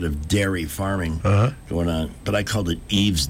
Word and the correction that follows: Ives: Dairy instead of Ives.